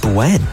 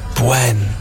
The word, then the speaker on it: bueno: Actually, it's pronounced Bwen. Bwen. Bwen.